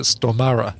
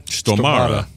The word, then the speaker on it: Stormare: He told me, his mother actually knows Peter Stormare's mother and says, "Here's how you pronounce it. You pronounce it Stomare." – Stomare.